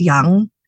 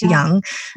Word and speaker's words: Yang: my maiden name is Yang, Y-A-N-G, but it's Young. It's pronounced Young.